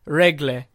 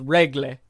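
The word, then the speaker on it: Rögle: Regle. That's how you say it. It's Regle. I've listened to it like 100 times.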